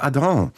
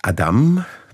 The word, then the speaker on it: Adam: Adolphe Adam, es ist auch die Aussprache Adam zulässig, denn sein Vater stammte aus dem Elsass, war Klaviervirtuose, Professor am Conservatoire de Paris, und da überrascht es besonders, dass er gegen eine Musikerlaufbahn des Sohnes war.